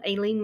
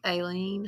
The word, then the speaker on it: Eilean: They built it on the largest of the little islands called Eilean Moor.